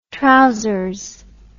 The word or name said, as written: trowsers